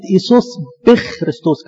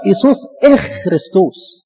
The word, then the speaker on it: ايسوس اخ كريستوس: وانا احبائي في غلطة برضو يعني بتصعب على الواحد قوي، لما الناس تنطق كلمة ايسوس بخ كريستوس، كلام ده غلط، بخ ده شيطان، قم اللي هي تتنطق ازاي؟ تتنطق ايسوس اخ كريستوس، عشان كده في القيامة نقول ايه كريستوس انستي، ما بنقولش ايه كريستوس بي انستي.